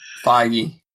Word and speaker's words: Feige: And speaking of that guy, Jeff Johns and what Kevin Feige, I'm saying that right? – Feige. – Feige.